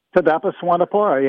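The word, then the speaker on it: Thidapa Suwannapura: Taidapa Suwanapura, yeah, you got it.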